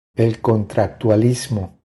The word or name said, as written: El contractualismo